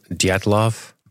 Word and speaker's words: Dyatlov: Dyatlov? – Dyatlov.